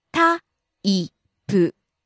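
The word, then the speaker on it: Ta-i-pu (タイプ): Ta-i-pu. Type. – Falling on the second syllable, i.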